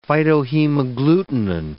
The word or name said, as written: phytohemagglutinin